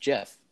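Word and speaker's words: GIF: I also found out it's officially pronounced Jif, like the peanut butter.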